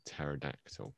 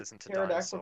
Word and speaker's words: pterodactyl: Why can't you hear the pterodactyl go to the bathroom? – I don't know. – Because it has a silent P.